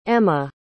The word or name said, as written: ema